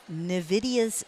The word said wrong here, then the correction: NVIDIA: Did I pronounce that right? – It's NVIDIA, but yeah, it's kind of hard to say.